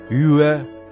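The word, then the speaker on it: 越: Юэ произносится в четвертом тоне.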